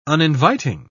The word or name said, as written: uninviting